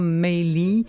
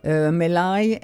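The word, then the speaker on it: Malye: » Voilà, sur une marche funèbre typiquement louisianaise, vous écoutiez un extrait de la Louisiane de Julia Maley, Maley, M-A-L-Y-E. C'est comme ça que ça s'écrit, lu par Valérie Muzzi, un bon livre à écouter par les oreilles et qui dure 12h45.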